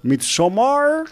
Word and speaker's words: Midsommar: llamado Midsommar. – O sea, de pronunciar así. – Sí, es en sueco. Sueco antiguo. Es en sueco antiguo.